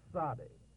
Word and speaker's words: Sade: Number 18, Sade, is a hissing S sound. Sade.